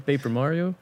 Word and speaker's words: Paper Mario: Pepper Mario? – Bijna niet, uitge bijna niet uitgesproken, Paper Mario.